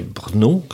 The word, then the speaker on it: Brno: le premier dégagement qui était Brno, que je prononce B, voilà, euh, je prononce bien B-R-N-O.